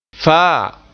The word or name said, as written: faa